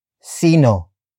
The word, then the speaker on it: sino: Sino, kina, Jack, at, Jill, ang, umakyat, sa, Borol. Sino, kina, Jack, at, Jill, ang, umakyat, sa, Borol. First, we have sino, which is spelled as S-I-N-O. It means who. Followed by kina. Kina. Kina. Kina is spelled as K-I-N-A.